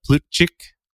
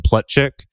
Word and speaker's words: Plutchik: Plutchik wheel. And he was he was actually American, so they probably called him Plutchik but Plutchik is probably how his ancestors pronounced the name, who was developed by psychologist Robert Plutchik in 1980.